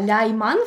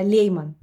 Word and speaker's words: Лейман: Я Лайман в Германии сейчас, потому что вот сочетание Е и И читается как Ай. Вообще я Лейман.